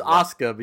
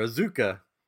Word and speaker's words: Asuka: Not part of the actual It was Asuka, but you call her Azuka. – Asuka, that's how you spell it. Asuka, right? – Like, here's Asuka. I'm like, it's Asuka.